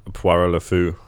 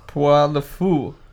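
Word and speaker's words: Pierrot le Fou: I don't know how to say his name. Puree? – Poirot Le Fou. – Poirot Le Fou.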